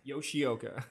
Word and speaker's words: Yoshioka: Yoshioka.